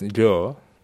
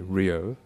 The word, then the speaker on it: Ryo: My name is Ryo Ikeshiro. – And how do we pronounce it here in Sweden? – And it's up to you. So in Japan it's Ryo. In the UK, people call me Ryo because that's how it's written. It's R-Y-O.